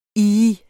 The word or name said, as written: ih